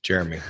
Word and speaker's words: Jerremy: So Jeremy is spelled with two R's. My dad's name was Jerry. So it's Jerry and me, Jeremy. That's how that happened.